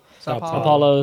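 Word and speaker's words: São Paulo: Sao Paulo.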